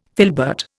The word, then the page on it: filbert: Indian